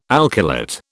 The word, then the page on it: alkylate: British English